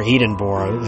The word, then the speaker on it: Edinburgh: It was particularly ancient, either, at least in terms of the city of Edinburgh, in which it was located, or Edinburgh, as it should be pronounced.